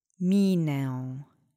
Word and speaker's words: minę: I am going to teach you ę because you do hear it slightly when this word is spoken. Let's say minę together three times. Accent is on the first syllable.